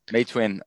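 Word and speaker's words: Meituan: Which I don't know the tones, but it's May Twin is the approximately the pronunciation.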